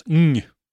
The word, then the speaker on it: Ng: eh, kommer då att bestå av James Dean, Daigo Saito och Charles Ng.